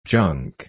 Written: junk

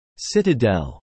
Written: Citadel

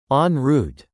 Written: en route

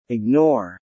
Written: ignore

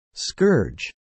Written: scourge

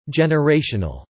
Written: generational

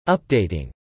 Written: updating

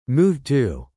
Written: move to

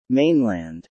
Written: mainland

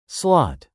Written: slot

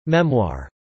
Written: memoir

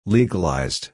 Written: legalized

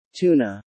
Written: tuna

